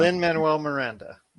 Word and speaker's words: Lin-Manuel Miranda: With this one, I think Lin-Manuel, I'm sorry, I can't even pronounce Lin-Manuel Miranda.